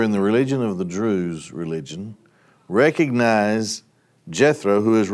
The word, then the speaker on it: Druze: Druze, D-R-U-Z-E. Maybe S-E. Druze, you know, is how you pronounce it.